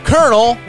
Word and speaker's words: colonel: This is called Why Do We Say Kernel Like Kernel? – Oh. – That's it. See, okay. Now a kernel is spelled colonel. – Oh, yeah. It's a weird spelling.